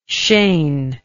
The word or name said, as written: shane